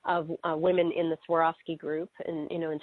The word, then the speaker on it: Swarovski: But then, boom, the I'm gonna say it properly again, Swarovski.